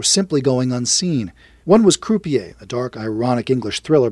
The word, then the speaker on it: Croupier: I think he referred to it as crappier, the British pronunciation is croupier, and it didn't play any major film festivals.